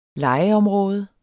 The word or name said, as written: legeområde